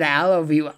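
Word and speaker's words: Alovue: I should clarify that. So it's spelled ALO. Vue, Yeah. – Project Alavu, and it's right play on I love you because it's an app and an algorithm that's capable of love.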